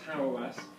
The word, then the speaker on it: Hauerwas: Our next speaker is Stanley Hauerwas, because I know my German, but apparently it's pronounced Hauerwas.